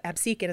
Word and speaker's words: Absecon: Absecon, as the locals apparently call it, or Absecon Island, but Absecon is how locals say it, so I'm going to say it that way, is where Atlantic City was built, and it was known to the Lenni Lenape Indians as Absagami, or Little Seawater.